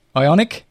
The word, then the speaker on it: Ioniq: So here's another new EV car coming along: Hyundai Ioniq. – Ioniq.